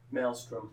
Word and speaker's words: Maelstrom: Is that how you pronounce that? – Maelstrom.